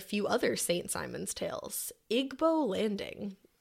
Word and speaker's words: Igbo Landing: Oh, that's British, so it sounds better. – She said Igbo. – Igbo Landing. – Igbo Landing. – You can just say it like that.